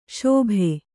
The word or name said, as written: ಸೋಬೆ